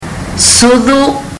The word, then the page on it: sucu: fiji